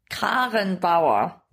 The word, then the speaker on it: Karrenbauer: Kramp. – Karrenbauer. – Karen. – Karen. Karen. – Spell it. – K-A-R-R-E-N. – Karrenbauer.